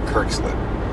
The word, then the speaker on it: Kerkeslin: Kerkeslin, Mount Kerkeslin, there we go, Kerkeslin. There's more E's than there should be. Now I'm fucking it up again. Kerkeslin, we'll call it.